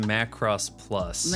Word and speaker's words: Macross Plus: It says Marquee Plus Archives. – Okay, it's pronounced Macross Plus.